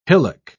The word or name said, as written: hillock